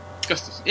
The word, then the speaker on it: Gustafsson: Gustafsson, yeah.